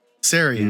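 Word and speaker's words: Cerean: I don't exactly know how to pronounce it, but it's C-E-R-E-A-N. – Sarian. – Sarian? – Yeah. – I always want to hard pronounce every C.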